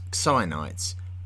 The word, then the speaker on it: Xionites: spelt X-I-O-N, and I am choosing to pronounce it as Xionites based on what I have read.